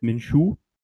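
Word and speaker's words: Minshew: Er heißt Minshu. – Ähm, also kein A hinten, sondern E.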